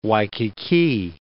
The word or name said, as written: Waikiki